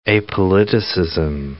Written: apoliticism

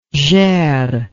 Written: gers

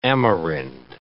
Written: Amerind

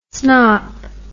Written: snop

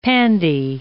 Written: pandy

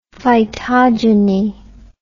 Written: phytogeny

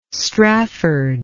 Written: strafford

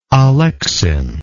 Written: alexinic

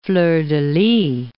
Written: fleur-de-lys

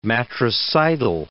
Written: matricidal

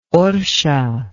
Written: orsha

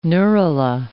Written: neurula

